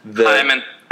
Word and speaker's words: Heyman: Because and I saw this article, and it was like a John Heyman article, or Hyman, or however you pronounce it. – Hyman.